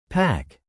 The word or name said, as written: PAC